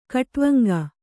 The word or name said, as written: ಖಟ್ವಾಂಗ